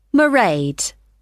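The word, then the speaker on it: Mairéad: It's M-A-I-R-E-A-D. Maraid.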